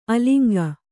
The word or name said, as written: ಅಲಂಗ